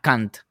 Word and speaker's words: cunt: una Kant, que se escribe C-U-N-T en, inglés y es como si te dijeran,